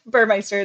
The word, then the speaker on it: Burmeister: Burmeister, it's perfect.